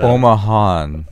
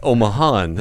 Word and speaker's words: Omahan: You do have a new release that just came out from fellow Omahaian? – Omahite? – Omahite? What's the proper way of saying that? – Omahan. – Omahan. There we go. Yes. Got to blur that syllable together.